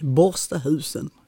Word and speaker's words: Borstahusen: Du, Siv Gunnel Margareta Malmqvist, född den 31 december 1936 i nordvästra delen av Landskrona i fiskeläget Borstahusen. Uttalar jag det rätt nu? – Helt rätt. Borstahusen.